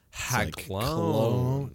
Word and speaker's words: Haglogne: We got R&D working on Haglogne. – Haglogne. – It's like cologne.